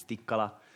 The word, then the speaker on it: Tikkala: To, to, just to remember uh, maybe how, how the last name is pronounced. It's Tikkala sounds similar to an Indian food, tikka masala or something like that.